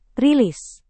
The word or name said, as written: rilis